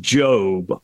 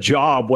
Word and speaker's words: Job: You have the patience of, I think it's pronounced Job. I said Job when I was a kid because that's the way it's spelled.